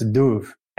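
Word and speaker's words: dubh: That BH is pronounced like a V, so that's duv, and that means black.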